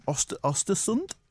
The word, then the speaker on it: Östersund: Right, now he was managing Östersund, which is the correct way to pronounce it.